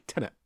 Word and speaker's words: Tenet: See, I said it right this week, Tenet.